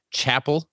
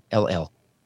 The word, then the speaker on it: Chappell: His last name is pronounced Chappell, but it's spelled with two P's and two L's, like Chappell.